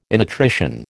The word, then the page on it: innutrition: American English